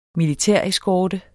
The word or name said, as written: militæreskorte